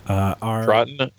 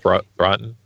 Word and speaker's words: Troughton: Troughton, I think that's how you say it, right? – We'll go with that. – Troughton.